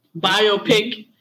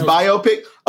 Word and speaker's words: biopic: It's biopic. Oh, I've been saying it wrong.